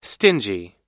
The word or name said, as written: stingy